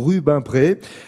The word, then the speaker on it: Rubempré: Alors ça s'écrit Rubempré, mais en Picardie, on dit plutôt Rubempré. R-U-B-E-M-P-R-E, 730 habitants,